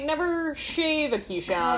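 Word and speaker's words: Keeshond: And I did, like, a Google on how to pronounce it correctly. It's Keyshound.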